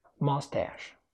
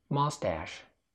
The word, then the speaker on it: mustache: Mustache نطقها البريطاني كده. Mustache أو mustache. يا إما تقول مو أو ما، ولكن في النهاية هتقول تا آ تاش مستاش، ولكن في الأمريكي mustache. Mustache. البريطاني mustache. وللأمانة لما بنسمعها من الإنجليز بيقولوها كده mustache، بصراحة ما بنقدرش نمسك نفسنا وبنضحك. Funny شوية. إنما النطق الأمريكي mustache.